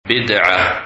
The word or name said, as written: Bidah